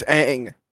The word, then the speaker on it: Aang: If his name isn't pronounced Ong, it's not Ong. It's Aang. – Yeah, get it right. – And then also another character looks fucking. Fire Lord Ozai looks good. Zuko looks good. Aang looks good.